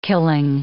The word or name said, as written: killing